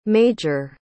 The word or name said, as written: maj